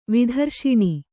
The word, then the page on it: ವೇತ್ರಾಸನ: vētrāsana